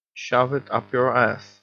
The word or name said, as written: shove it up your ass